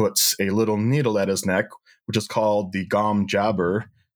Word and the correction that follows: Gom Jabbar: unless you guys want to pronounce it differently. – Gom Jabbar. – Jabbar. Gom Jabbar.